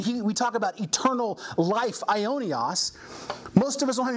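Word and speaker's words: Ionios (αἰώνιος): It's pronounced Ionios. Ionios.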